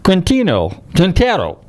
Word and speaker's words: Quintero: I'm delighted to welcome to the show this evening Juan Carlos Quintero.